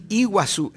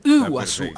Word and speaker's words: Iguazú: Ahora, si vos decís Iguazú, está diciendo hijo, chico grande. – Claro. – Es una contradicción. – Claro, si vos tenés que pronunciar bien para que tenga el sentido verdadero. Iguazú, aguas grandes.